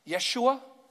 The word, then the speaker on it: Yeshua: is because it's connected to the name Joshua, which would be pronounced Yeshua, which is a lot like the name Jesus, which is Yahweh saves.